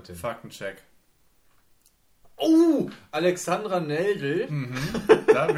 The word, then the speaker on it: Alexandra Neldel: Sie heißt wirklich Alexandra Neldel. Da hätte ich sie einfach nur richtig aussprechen müssen.